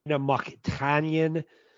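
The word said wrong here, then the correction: Mokhnatkina: I said that wrong. It's Mokhtanakina is what it is, right? – Mokhtanakina.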